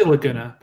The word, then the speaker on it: Laguna: You know, I had the dream sequence with Laguna. Is that how I pronounce it? Laguna? – I say Laguna. But Laguna? Okay. Like Laguna Beach. Okay. – Yeah, that's how I say it.